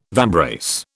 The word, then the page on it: vambrace: British English